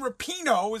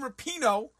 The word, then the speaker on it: Rapinoe: Or do I start out with this pinhead, Megan Rapinoe? Is that how you say it? Pinot, like Pinot Grigio? Megan Rapinoe, who was the women's soccer's hero.